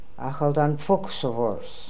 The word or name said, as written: Akhaldanfokhsovors